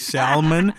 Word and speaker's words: salmon: Well, you are not the first and only person to say salmon, and you guys need to go away because the L is silent.